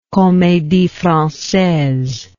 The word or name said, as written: comedie francaise